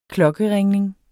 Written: klokkeringning

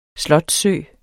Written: slotssø

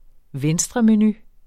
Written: venstremenu

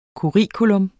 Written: curriculum

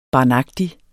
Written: barnagtig

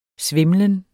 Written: svimlen

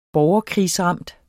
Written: borgerkrigsramt

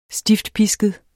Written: stiftpisket

